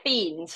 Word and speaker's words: Thien: It's Thien. So because it's actually a silent H, it's a silent H and most people would just call it Thien.